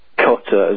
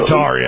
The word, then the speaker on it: Qatar: Apparently they're unhappy with the government of Qatar, as Americans call it, or Fox News created that. I think it's actually pronounced Qatar, or it is in Europe. – Yeah, Qatar, yeah.